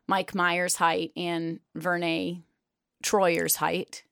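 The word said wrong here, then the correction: Verne Troyer: Vern Troy. – What? – It's Vern Troyer.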